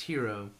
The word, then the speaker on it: Hero: almost say it like gyro, but it's hero.